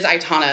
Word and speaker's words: Aitana: I think it's Aitara. – Aitara or Aitana? It's got an N. – I'm sorry. It is Aitana.